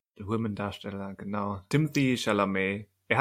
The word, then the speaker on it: Timothée: Also ich glaube, er, ich glaube, die korrekte Aussprache ist mehr diese französische Timothée, mhm. weil er ja mit, mit TH, e, äh, e mit Akzent und noch ein E geschrieben wird.